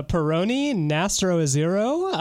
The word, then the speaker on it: Peroni Nastro Azzurro: That is a Peroni Nastro Azzurro. That was so bad. I butchered that. – No, that was pretty close.